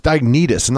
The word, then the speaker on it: Dionysius: you see it in a letter to Dionysius. It's Dionysius, not Nietzsche. Yeah, because it's like it's U.S., not I-U-S.